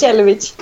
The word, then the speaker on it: Jelovic: Nu ska jag uttala efternamnet. Jag, jag har alltid fel på var jag ska lägga betoningen. – Jelovic okay. – vill jag säga. – alltså fantastiskt. Första rycket gick jättebra. – Det var det alltså? Ja, det är precis så man säger det. Jelovic.